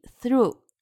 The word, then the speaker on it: through: 注意 through 一定要咬舌，th 一定要咬舌。